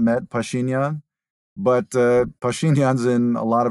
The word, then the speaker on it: Pashinyan: So, what do you think happens to the Armenian Prime Minister, if I pronounce his name correctly, Nikol Pashinyan? – Pashinyan, mm-hmm.